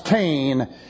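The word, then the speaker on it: tain (τὴν): That's T-E-N. And again, it occurs, T-E-N, pronounced tain.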